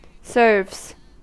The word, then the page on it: serves: American English